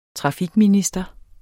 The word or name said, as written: trafikminister